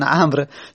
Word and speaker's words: عمرو: میگه میگه عمر میبینی که وقتی که مینویسن عمر، یک وو کنارش میذارن، یعنی عمرو، یعنی یک وو میذارن دیگه. خب این که مستحقش نبوده عمر یک وو بگیره. عمر عین میم راه هست، وو که نداره تلفظ نمیزنیم.